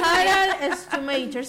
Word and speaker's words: Harald Schumacher: Harald Schumacher.